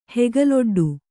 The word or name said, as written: ಹೆಗಲೊಡ್ಡು